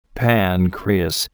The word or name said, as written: pancreas